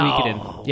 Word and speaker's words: Suikoden: Is it really Suikoden? – Suikoden is actually no way. – Yeah, it is. – No. – Suikoden.